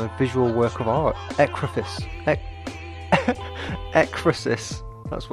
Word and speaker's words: Ekphrasis: It's called Ekphrasis. That's how you pronounce the word Ekphrasis.